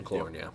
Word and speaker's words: McLaurin: Terry McLaurin, I believe that's how you say it. – McLaurin, yeah.